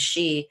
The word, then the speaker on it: sidhe: I'm using the term fairy or she, S-I-D-H-E, mm-hmm. the pronunciation is she, to describe this group of beings on the other side.